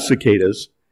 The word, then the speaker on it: cicada: Cicada. Soft C, hard C, D. So the consonants S, K, D.